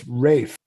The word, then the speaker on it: Ralph: It is Rafe, isn't it? Rafe Fiennes? – I think they're two different guys. There's Ray Fiennes, R-A-Y, and then there's Ralph. – No, it's Rafe. – Rafe. – What? – R-A-L-F-F.